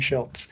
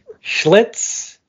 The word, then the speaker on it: Schlitz: I wrote down in the notes how to say it. Schlitz. I don't know why I was saying Schultz. Schlitz.